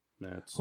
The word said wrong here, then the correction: Martz: You could say his name right to start. – What is it? – Martz.